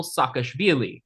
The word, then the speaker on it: Saakashvili: There was a contested parliamentary election, and Shevardnadze resigned in order to be replaced by Mikhail Saakashvili.